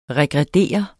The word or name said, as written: regrediere